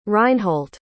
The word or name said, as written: reinhold